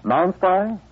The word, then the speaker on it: Nonspi: pronounce N-O-N-S-P-I? – Non-spy?